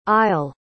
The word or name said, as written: i'll